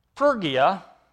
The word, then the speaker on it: Phrygia: Phrygia, and the Y there is not pronounced like a Y. It's in the Greek, it's an upsilon, so it's phu.